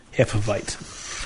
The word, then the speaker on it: Hefeweizen: as most of the southern Germans I have encountered pronounce the W as a soft V, Hefeweizen.